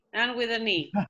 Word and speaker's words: Anne with an E: Anne with an E, ¿lo pronuncié bien? – Sí, Anne with an E.